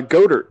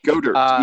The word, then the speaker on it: Goedert: Your tight end that you guys drafted, Dallas, what is it, Goedert, actually. – Goedert, okay. – Goedert, yes, the T is pronounced.